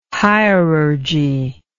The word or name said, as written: hierurgy